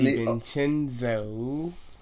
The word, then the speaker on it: DiVincenzo: And DiVincenzo. – Did I say that wrong again? – No, you said it right.